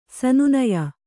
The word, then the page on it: ಸನ್ನಾಯ್ಯ: Kannada